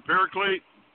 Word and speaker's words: Paraclete: Paraclete? Is that right? – Right. – Yes. – The Holy Ghost being a paraclete, it's there alongside us, not like a crutch.